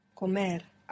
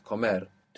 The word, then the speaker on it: comer: Comer. – Accent on the end. Comer. To be, for states.